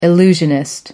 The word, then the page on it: illusionist: American English